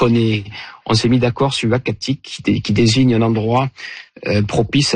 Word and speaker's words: Uhackatic: Alors, le nom, ça sera donc Uakatik, et ce nom-là, donc il s'écrit U-H-A-C-K-A-T-I-C, nous a été proposé par le Conseil des Inuits d'Ekunitchit, qu'on a contacté justement pour avoir leur avis.